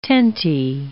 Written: tentie

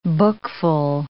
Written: bookful

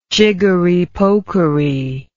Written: jiggery pokery

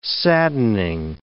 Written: saddening

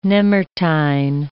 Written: nemertine

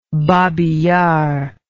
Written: Babi Yar

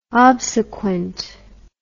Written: obsequent

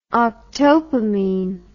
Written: octopamine

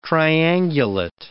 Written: triangulate

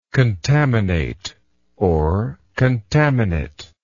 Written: contaminator

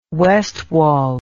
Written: Westwall

